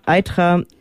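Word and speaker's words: Eythra: Ich wollte auch nochmal erwähnen, dass eben dieses Dorf Itra, habe ich das jetzt richtig ausgesprochen? Egal. – Aitra, genau, ein Dorf bei Leipzig ist, was ja weggebaggert worden ist, wo es jetzt einen großen Tagesee gibt.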